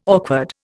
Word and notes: awkward: Indian

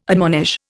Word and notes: admonish: Indian

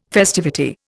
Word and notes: festivity: Indian

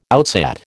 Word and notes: outsat: American English

